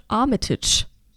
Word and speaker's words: Armitage: sondern auch in dieser Serie spielt Richard Armitage, ich weiß es nicht. – Richard Armitage.